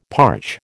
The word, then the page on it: parch: American English